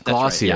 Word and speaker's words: Glossier: That's Glossier.